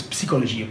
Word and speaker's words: psychologie: By the way, in French, it's psychologie. I pronounce the P, which is the weirdest thing when I first learned that. It's like, oh, it's psi.